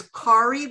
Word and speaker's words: Kari: And Stephanie, my name is Kari, which rhymes with sorry. – Uh-huh. – Like the Indian sorry.